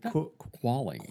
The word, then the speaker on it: Qualley: Qualley.